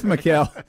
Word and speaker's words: Mikal: Have I been saying his name wrong this whole time? – No, I think Matt did. – Okay. – I probably said it wrong. – It's Mikal.